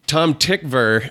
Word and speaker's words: Tom Tykwer: Tom Tykwer, as it is pronounced, even though it's spelled T-Y-K-W-E-R. – Right, Tykwer.